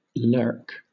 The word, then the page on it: lurk: British English